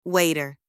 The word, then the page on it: waiter: anglais